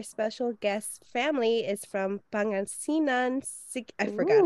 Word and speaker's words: Pangasinan: Pangasinan and Siquijor.